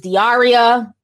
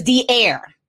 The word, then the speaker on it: D'Air: I had assumed the way it was spelled. It was Diaria. But then I went to, you know, get her name pronunciation. I had the Google hoodie. And her name is D'Air. So like D and Air.